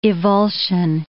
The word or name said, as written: evulsion